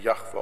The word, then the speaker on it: Jahwe: Dieser hebräischen Konsonanten JHWH, den wir mit Jahwe aussprechen.